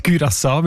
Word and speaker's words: Curaçao: Mit Ü, genau. Das ist der holländische Einfluss. Und wo, weil es gehört ja zu Holland, ganz offiziell. Das sind eben die Aussprachendatenbank. Die haben nämlich gerade letzten Dezember die Aussprachempfehlung geändert von Curaçao auf Curaçao, weil sie beziehen sich dort auf eine Aussprachenduden und auf das digitale Wörterbuch von der deutschen Sprache, wo beide eben Curaçao vorschreiben.